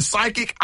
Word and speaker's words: psychic: So you're right, because when we see psychic, it's spelled P-S-Y, right? – I couldn't tell you. – Yes, yeah. – So the P is silent.